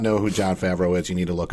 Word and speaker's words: Jon Favreau: All right, Jon Favreau.